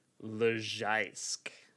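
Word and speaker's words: Leżajsk: But it's Lezajsk. – Lezajsk? – So the J is like an I and the Z is like a J.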